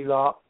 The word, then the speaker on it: Lark: My email is Jimmy Lark.